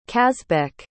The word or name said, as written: kazbek